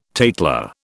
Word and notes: tatler: British English

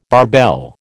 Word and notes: barbell: American English

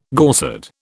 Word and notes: Gorsedd: British English

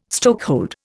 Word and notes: stokehold: Indian

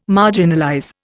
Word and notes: marginalize: Indian

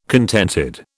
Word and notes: contented: British English

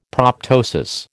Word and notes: proptosis: American English

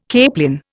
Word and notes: capelin: Indian

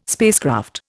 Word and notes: spacecraft: Indian

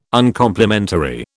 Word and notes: Uncomplimentary: British English

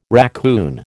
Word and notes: raccoon: American English

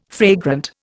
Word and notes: fragrant: Indian

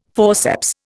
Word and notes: forceps: Indian

